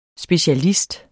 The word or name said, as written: specialist